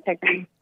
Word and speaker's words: Tigray: Tigray is how it's pronounced? – Tigray. Yeah, Tigray.